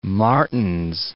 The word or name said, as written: Martens